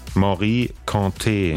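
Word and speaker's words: Mory Kanté: Wie wird's ausgesprochen? – Morikante. – Ach so, guck mal. Das war also falsch.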